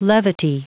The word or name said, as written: levity